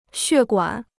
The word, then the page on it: 血管: xuè guǎn